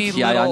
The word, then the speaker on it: Hyundai: Excellent pronunciation of Hyundai. – Little, yeah, sorry, what was that? – I was saying you pronounced Hyundai very correctly. Yeah, Hyundai. – A lot of people just say Hyundai.